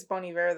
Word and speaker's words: Bon Iver: Bon Iver? – Bon Iver. – Yeah, I think it's Bon Iver, though. – Oh, that's awkward. – Because he's French.